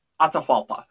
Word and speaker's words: Atahualpa: I'm going to try to pronounce his name, Atahualpa. There we go. That's it. Atahualpa.